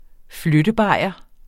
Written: flyttebajer

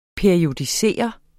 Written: periodisere

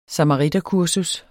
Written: samariterkursus

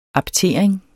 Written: aptering

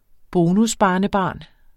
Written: bonusbarnebarn

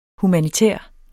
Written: humanitær

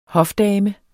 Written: HOFDAMER

